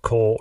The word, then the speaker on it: Kole: I am your host, Cole Ross.